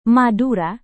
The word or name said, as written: madura